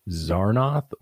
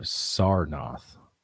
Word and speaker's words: Sarnath: Real question, is it Zarnoth or Sarnoth? – Sar with the S.